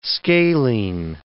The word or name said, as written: scalene